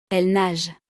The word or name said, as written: elles nagent